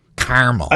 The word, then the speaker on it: caramel: Caramel. – I don't think you get the carabit. I think it's just caramel. That's caramel. – It. – Yeah, two syllables. You got an extra syllable in there.